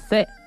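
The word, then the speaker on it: se (四): Porque su pronunciación en mandalí es se. Y suena similar a la palabra muerte, que es se.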